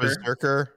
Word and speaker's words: BRZRKR: How do you even pronounce this? – It's Berserker. – Berserker? – Yeah. – Because B-R-Z-R-K-R.